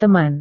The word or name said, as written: teman